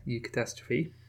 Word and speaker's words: eucatastrophe: Is that how you say it? You're the resident pronunciation expert. – Yeah, that's fine. Yeah, eucatastrophe. So that's from the Greek, obviously, eu meaning well, and like eugenics, getting your genes done correctly.